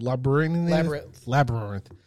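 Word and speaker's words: Labrinth: Labyrinth.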